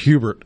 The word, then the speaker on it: Hubert: Hubert can sound, and I'm not, no offense to anybody if your name's Hubert, but it can sound a little funny when it's pronounced in English. But over in Europe, if you're close, the closer to France you get, the closer to pronunciation, like the French Wouldn't it be something fancy like Hubert? – Mmm, Hubert.